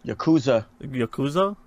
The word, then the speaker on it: Yakuza: Yokozua, did I say that right? – Yakuza 6.